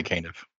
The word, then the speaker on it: Caniff: And the thing is, Milton Caniff, or I think that's how you say his name, right? It's Caniff. – I say Caniff.